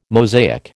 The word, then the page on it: Mosaic: American English